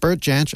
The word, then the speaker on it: Bert Jansch: Bert Jansch, and yes, I did check the pronunciation of that name, was one of the most influential guitarists of the 1960s.